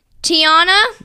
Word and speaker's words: Tiana: Tiana.